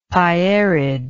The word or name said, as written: pierid